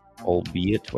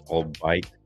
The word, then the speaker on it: albeit: As someone who has only seen it written, it's pronounced, albeit, wait, what? All of that, I swear, if I hear him pronounce all bite, like it's all bite one more time, I'm going to chew off my own tongue.